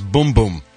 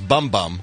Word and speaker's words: Bumbum: Apparently, it's Miss Boom Boom. – Boom Boom. – Boom Boom. – Boom. – I was saying Miss Bum Bum. – Right. – Because it's spelled B-U-M-B-U-M.